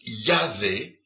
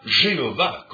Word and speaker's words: Yahvé: ce qui en hébreu se prononce « Yahvé » et non pas « Jéhovah » comme ils le prétendent, comme les Témoins de Jéhovah le prétendent. C'est donc « Yahvé » comme le prononcent encore tous les Juifs aujourd'hui.